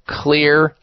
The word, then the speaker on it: Xlear: And it is called Clear Nasal Wash or Clear Nasal Spray. And Clear is spelled X-L-E-A-R. The X stands for xylitol.